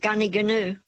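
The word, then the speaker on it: Gnoo Gnoo: It's spelt GNOOGNOO, but it's called Gunniganoo.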